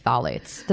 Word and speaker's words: phthalates: Like, I still can't say phthalates. Did I get that right? – Yeah, you did.